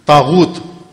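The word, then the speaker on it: طاغوت: ایک مجلس میں تاغوت, 10 دن تاغوت پر مجلس پڑی, تاغوت غین کے ساتھ.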